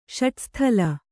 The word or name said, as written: ಷಡುಸ್ಥಲ